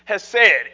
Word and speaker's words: hesed: And what all of those English translations are trying to do is take one Hebrew word that's spelled in English, H-E-S-E-D, has said.